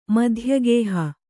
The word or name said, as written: ಮಧ್ಯಗೇಹ